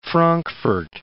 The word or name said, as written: Frankfurt